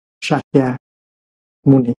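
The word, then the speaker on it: Śākyamuni: thì tên của ngài ngày xưa là mình đọc theo người người hoa mình đọc là thích ca mâu ni bây giờ mình phải đọc lại cho đúng sakya muni buộc sakya muni đó là tên đúng của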